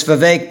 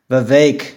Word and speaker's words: Vivek: Oh, it was Vivek or Christie, right? – That's another one. Pence or Vivek. – Oh, okay. – And it's Vivek, by the way, to you, Vivek? – Noah. Vivek.